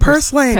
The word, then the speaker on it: purslane: Purslane, am I saying it right? – Purslane, yeah. Purslane, okay.